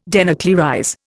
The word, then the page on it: denuclearize: Indian